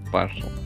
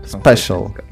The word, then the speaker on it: special: Спашл. – Спашл. Это читается как спашл. Сейчас я даже... – Что нам Google Спешл. Смотри, читай как спешл э, английский почему-то.